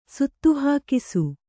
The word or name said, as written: ಸುತ್ತುಹಾಕಿಸು